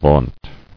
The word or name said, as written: Vaunted